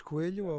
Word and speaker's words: Coelho: Mein Name ist Antonio oder Antonio Fernandes Coelho auf Portugiesisch ausgesprochen.